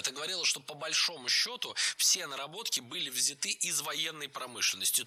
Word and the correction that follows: взяты: Не взяты, а взяты. Ударение в этом слове сохраняется на первом слоге. Он взят, оно взято, они взяты. И только лишь в женском роде ударение переносим на окончание. Она взята.